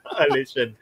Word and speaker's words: Alsatian: And Alsatian is also an animal, although it's Alsatian. – Alsatian. He never corrects me, you know, not once, you know, for like so many episodes that was like, okay, bro, it's Alsatian, like the dog, okay, not Alsatian.